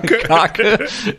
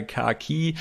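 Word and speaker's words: Kaake: Wie spricht man den aus? Ja, Kacke. – Ja, Kaki. Der so dieser klassische,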